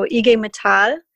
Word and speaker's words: IG Metall: So IG Metall is how you pronounce the German Metal Workers Union in German.